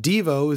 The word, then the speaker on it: Devo: For example, an old interview they aired with Alan Myers and Mark Mothersbaugh of Devo, I found out Devo is actually pronounced Devo, at least by its members.